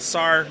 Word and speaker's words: Sahr: Sar rhymes with car with a silent H, so S-A-H-R.